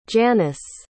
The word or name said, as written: janyce